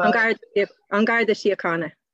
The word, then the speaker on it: An Garda Síochána: Well, could you say how they pronounce the Garda? – An Garda Síochána.